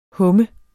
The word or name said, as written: humme